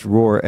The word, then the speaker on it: Roar: We went from zero to millions, and it's pronounced roar as in the Katy Perry song, right? – Roar like a lion.